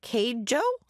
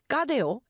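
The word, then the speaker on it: Cadejo: I'd be like, that's not K-Jo. I'd be like, what? But it's pronounced Gadeo.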